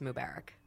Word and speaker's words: Mubarak: I think the emphasis is on a different syllable, so it's Mubarak.